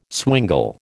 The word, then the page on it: swingle: American English